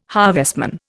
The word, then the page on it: harvestman: Indian